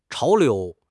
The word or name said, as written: 潮流